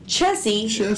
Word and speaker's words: Chessie: Chessie. – To rhyme with Nessie, the Chesapeake Bay's own blurry camera shy water monster.